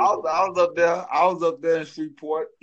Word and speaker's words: Shreveport: Shreveport. All right, Shreveport, Louisiana.